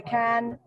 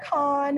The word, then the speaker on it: Cannes: So Cannes has and I learned the proper pronunciation is to say can. – Yes. – Not con.